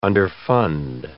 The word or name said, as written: underfund